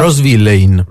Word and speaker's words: Rose Villain: Rose Villain.